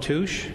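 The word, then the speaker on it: tusche: is actually a material that's called touche. It's pronounced T-O-O-S-H, but it's spelled differently than that.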